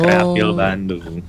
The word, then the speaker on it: Kreafil Bandung: Eh mahasiswa bener ya, Kreafil Bandung, betul dibacanya? – Betul banget Kak, oh. Kreafil Bandung.